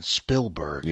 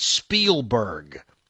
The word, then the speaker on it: Spielberg: That was Mike Evans, who, by the way, still doesn't know how to pronounce Spielberg's last name. He calls him Steven Spielberg. – Yeah. – He always does that. It's Spielberg, not Spielberg.